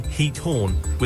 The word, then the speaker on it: Giethoorn: The pronunciation of Giethoorn is approximately Giethoorn, with a guttural ch sound similar to the German ik sound.